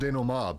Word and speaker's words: denouement: And I'm like, oh wait, that's French. It's pronounced denouement, but it's like, okay.